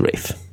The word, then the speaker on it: Ralph: And despite the fact that his name is Ralph, it's pronounced Ralph.